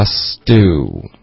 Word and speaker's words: stew: 的音，然后后面有一个 u，那就是 stew，然后 stew 的这个发音呢，美式的发音就完全不一样了。我们会发 stew，是一个的的音，stew，stew，对，然后就是 do something 的 do，我们不会说 do，对不对？do something，这样也很奇怪，我们会发 do，对，那这就是前面加一个 s 的音，stew。